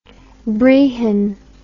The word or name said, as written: brehon